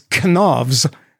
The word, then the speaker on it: Knavs: It is, and I'm going to do the German way because they would pronounce the K. It is Knavs. That's what you say in Swedish. K-N-A-V-S.